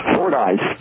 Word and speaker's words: Fordyce: Is that how you pronounce that? I've never really done that right, even though I've been heard in that town for a long time. Is it Fordyce? – Fordyce.